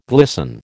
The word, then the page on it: glisten: American English